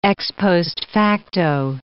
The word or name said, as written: ex post facto